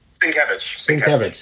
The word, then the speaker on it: Sienkiewicz: And I like Sal Buscema, but my least favorite inker of Sal Buscema comes on board when you have Bill Sienkiewicz, or Sienkiewicz, I forget how to pronounce it. – Sienkiewicz, yes, thank you.